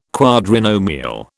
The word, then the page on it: quadrinomial: British English